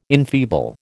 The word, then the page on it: enfeeble: American English